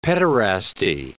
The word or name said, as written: pederasty